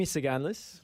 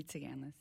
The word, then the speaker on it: Tsiganis: Did I say your name correctly? That's Tsiganis. – The first question. I'll let you say it. – Amy Tsiganis, yeah. Silent S in the middle.